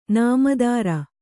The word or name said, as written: ನಾಮದಾರ